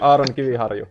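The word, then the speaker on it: Aron Kiviharju: I really like Aaron Kiviharju, if I'm saying it right. – Yeah, it's pretty close. You gotta just roll the R a little bit.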